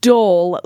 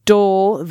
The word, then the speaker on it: Dhole: It's pronounced Dole, like the fruit company. He was named after Dhole, the burrowing horror from H.P. Lovecraft. I should have known that, not D Hole.